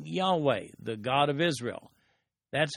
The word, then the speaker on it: Yahweh: But he also addresses him with the word Lord, all caps, from the Hebrew word Yahweh, sometimes pronounced Jehovah,